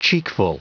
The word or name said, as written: cheekful